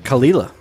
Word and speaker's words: Kalila: The next offering, Kawaila. Am I saying that right? – Kalila, I believe it Kalila. – Is. – Kalila. – You have to say it fast.